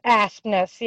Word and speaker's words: Aspnes: It's Aspnes, yep.